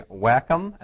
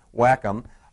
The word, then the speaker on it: Wacom: This is a Wacom, and that's actually how you say it. I just learned that today. So if you're wondering, Wacom.